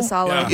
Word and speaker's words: Korpisalo: Korpisalo.